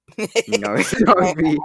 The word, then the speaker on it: Narfi: And then we have Narfi. Am I pronouncing that wrong? – Narfi. I don't know. Narfi? No, it can't be. It's N-A-R-F-I. – Narfi. – Narfi.